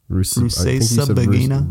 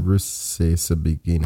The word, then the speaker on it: Rusesabagina: I feel Paul Rusesabagina. – Rusesabagina. Rusesabagina.